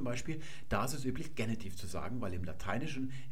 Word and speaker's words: Genitiv: Mir hat jemand eine E-Mail geschrieben und mich gefragt, warum ich immer, wenn ich Genitiv sage, Genitiv sage, mit einem kurzen e vorne. Eigentlich ist doch das e, die Silbe offen, deswegen muss im Deutschen das e lang gesprochen werden.